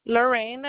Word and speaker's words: Lorraine: Lorraine Herrera. – Can you spell that for me? – L-O-R-R-A-I-N-E. Lorraine, and then Herrera, H-E-R-R-E-A-E-R-E.